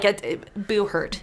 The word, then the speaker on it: Buhurt: He told us, I'm saying it wrong, fuck me in the face. But, like, Buhurt. – Buhurt. – Buhurt. Yeah. Okay. – That's how he says it.